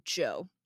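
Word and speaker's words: Zhou: I found out that you pronounce his name Joe.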